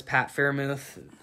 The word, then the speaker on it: Freiermuth: to see that great pick for them but they just needed o-line more uh, their second round guy was pat freiermuth i believe it's pronounced like i said